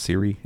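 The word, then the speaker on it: Ciri: And so Geralt will likely be the development board, but it's called, I guess it's pronounced Siri. It's C-I-R-I instead of S-I-R-I.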